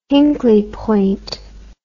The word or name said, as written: hinkley point